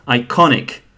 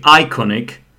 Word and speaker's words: iconic: So, iconic, where does the stress go? The way I'm pronouncing it, iconic. I'm not pronouncing it iconic. I'm not pronouncing it iconic. Claire pronounced it as iconic, which is the correct pronunciation, because obviously Claire is British and she can speak English well.